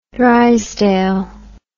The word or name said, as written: Drysdale